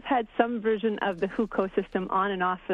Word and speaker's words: Hukou: And one problem for these migrants that you write about is the Hukou, is that how you say the word? – Yes, that is how you say the word.